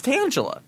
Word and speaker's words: Tangela: It's Tangela. – Oh, I always thought I was saying it wrong.